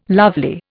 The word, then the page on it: lovely: Indian